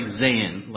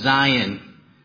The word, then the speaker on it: Zion: It looks like Zan, like just Zan, but it's Zion. I got that from two of our other pastors. They confirmed that that's how it's said in Hebrew.